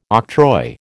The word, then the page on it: octroi: American English